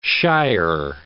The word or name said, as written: shyer